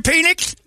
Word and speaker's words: Penix: And I also like the fact that Washington has a quarterback named Mike Penis. – Penix. – Is that how it's pronounced? I've been saying it wrong the entire time. I thought it was plural penis.